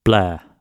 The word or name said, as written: blare